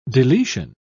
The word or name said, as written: deletion